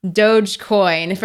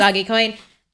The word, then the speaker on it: Dogecoin: You know, I'd go somewhere so that no one tried to kill me for my Dogecoin, which I just Googled, and it is pronounced Dogecoin for anyone out there. But Nicole and I forever call it Dogecoin.